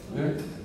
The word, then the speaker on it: bier: Then he went up and touched the bier. Bier? What's the correct pronunciation of that? – Bier.